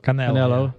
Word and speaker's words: Canelo: Is it Canelo Alvarez? – Canelo.